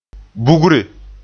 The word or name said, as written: bugre